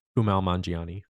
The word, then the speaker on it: Kumail Nanjiani: The comedian has Kamal... I can never say his name right. The guy from Silicon Valley, and then went on to be in Marvel and The Eternals. – Kumail Nanjiani.